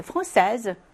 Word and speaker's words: française: Vous êtes française? 프랑스 사람입니까? 라고 하죠. 여성인 경우에는요, S를 붙이고, 이제 발음도 français, Z라고 하셔야 돼요.